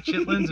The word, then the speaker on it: chitlins: Chitterlings, but it was not chitlins, it was chitterlings. Chick, it's chitlins. You're saying it wrong, first of all.